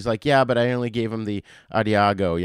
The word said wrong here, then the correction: Adagio: you know, Adagio.